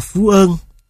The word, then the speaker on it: Phú Yên: Về xuất xứ của tác phẩm, các nhà sưu tầm cho biết, bài hò Phú Yên này, giọng Nam Trung Bộ đọc là Phú Ơn,